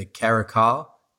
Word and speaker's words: caracal: which is the caracal.